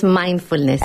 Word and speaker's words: mindfulness: ¿Lo he dicho bien? – ¿Guay? No, es mindfulness.